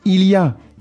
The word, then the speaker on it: Il y a: Il y a.